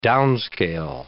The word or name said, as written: downscale